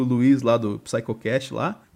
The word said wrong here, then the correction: Psicocast: Na verdade é Psicocast, eu falei errado o programa inteiro.